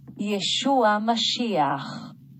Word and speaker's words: Yeshua HaMashiach: 我们给大家听一下哈，这个音怎么念？Yeshua Mashiach， Yeshua Mashiach， Yeshua Mashiach。 – 这是 Yeshua HaMashiach，然后这个后面这个 H 的音，喉咙音量非常重哈。